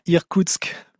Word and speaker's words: Irkoutsk: À Irkoutsk, voilà.